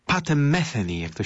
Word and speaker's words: Metheny: I że mówi się Metheny.